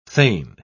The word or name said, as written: thane